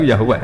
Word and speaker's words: Yahweh: Tapi ndak dosa syirik, ya paham ya, Tuhan Yahudi tunggal nyu Yahweh, napa? Ya. Iku nak teng ejaan Latin Y besar A H besar W E H besar Yahweh.